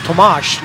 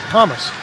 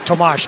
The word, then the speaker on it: Tomáš: He came up and told Randy Hahn and I that he'd like his name pronounced Tomash, not Thomas.